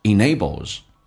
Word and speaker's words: enables: enable 后面加 s 发 z 这个发音， m n 跟 l 后面接 s 发 z，要声带震动一下 enables And 跟 enable 有一个连音，绝对不是用 d 来进行连音，你要相信 ndnt 结尾一般是 dd 去掉，用前面的 n 进行连音，叫 enables。